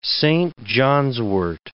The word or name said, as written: Saint-John's-wort